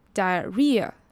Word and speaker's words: diarrhea: 而 diarrhea 这个字呢，首先它的 h 是不发音的，结尾的 ea 不是发 e，而是念 ear。 diarrhea 重点是这个字它的重音是在 r 的音，diarrhea。